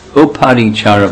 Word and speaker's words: Uparichara: And Shrutakirti, Kunti and Vasudeva's sister, married one of the sons of Viprachara Vasu. That's not the right way to say his name. Uparichara Vasu.